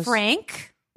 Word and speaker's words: Frank: On Instagram, at Chelsea, C-H-E-L-S-E-A, the correct way to spell it, Frank, like Anne.